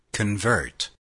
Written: convert

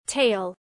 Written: tale

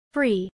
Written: free

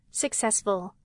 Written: successful

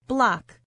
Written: block

